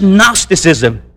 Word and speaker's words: Gnosticism: Because you see, some of the insidious teaching we know from first century writings, Gnosticism had gone into the church. What is Gnosticism? G-N-O-S-I-S-T-A-S-I-M. It's a silent G. Gnosticism.